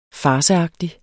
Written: farceagtig

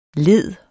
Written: lede

